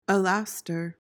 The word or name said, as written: alastor